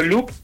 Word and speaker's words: Loop: Bueno, la red es muy fácil, es Campoloop, el proyecto, pero Loop, recuerden paisano que se escribe en inglés, es L-O-O-P. – L-O-O-P.